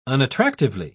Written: unattractively

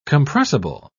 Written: compressible

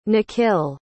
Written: nikhil

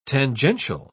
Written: tangential

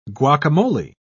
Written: guacamole

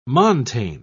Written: montane

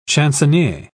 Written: chansonnier

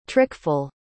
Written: trickful

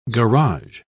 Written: garage

